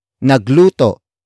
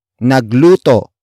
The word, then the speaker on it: nagluto: Nagluto is spelled as N-A-G-L-U-T-O. Note the glottal stop at the end.